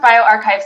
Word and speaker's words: bioRxiv: Yeah, it's pronounced BioRxiv.